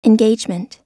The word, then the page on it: engagement: American English